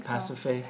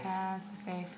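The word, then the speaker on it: Pasiphaë: Is that how you pronounce her name? – Pasiphae. Pasiphae from Greece.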